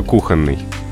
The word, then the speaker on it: кухонный: Правильно, только кухонный. Ударение на «у» и запомнить, ну, очень просто. Кухня и кухонный. – Угу. – Прилагательным кухонный, ударение на том же первом слоге.